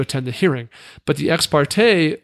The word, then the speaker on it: ex parte: Ex parte is fine.